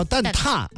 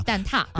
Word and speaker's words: 蛋挞: 其实呢它的官方读音叫蛋挞、对，蛋挞、